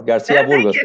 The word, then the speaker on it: Garcia Burgos: You said it well, Garcia Burgos.